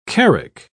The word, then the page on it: carrack: British English